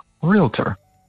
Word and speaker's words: realtor: It's just two syllables, y'all. Not realtor, not realtor, realtor.